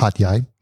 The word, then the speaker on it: Hat Yai: Haitai, I think it's called. – In Haitai. – And no, Hat Yai. – I always say it wrong. That's why I said it like that.